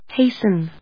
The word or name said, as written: hasten